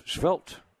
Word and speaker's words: svelte: how do you say that word, svelte, yeah, S-V-E-L-T-E, figure that you have.